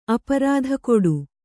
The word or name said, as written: ಅಪರಾಧಕೊಡು